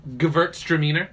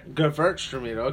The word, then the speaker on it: Gewurztraminer: Is that how you say it? – Gewurztraminer. – Gewurztraminer, okay.